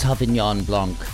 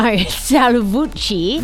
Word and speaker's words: Salvucci: It's pronounced Sauvignon Blanc. – No, Salvucci.